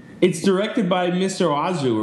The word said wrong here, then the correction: Oizo: How do you say that guy's name? Who Eric Wareheim did that video for? – Wait, what? – Mr. O-I-Z-O. – That's right, Mr. Oizo, yeah.